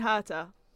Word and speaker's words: Herta: Colton Herta. I did pronounce that right? – Yeah, that's correct.